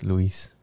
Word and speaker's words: Luis: My name is Luis Salazar, not Luis, not Louis, not Luis.